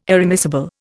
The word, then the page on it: irremissible: Indian